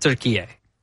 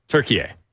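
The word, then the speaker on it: Türkiye: They pronounce it Turkiye. – Turkiye. – It's pronounced Turkiye.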